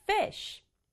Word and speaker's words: fish: 주의할 점은 fishy 이렇게 발음하거나 fishy 두 박자로 발음하지 않는 것. fish 끝부분은 sh 이렇게 바람 소리만 나기 때문에 fish 한 박자로 발음한다는 것.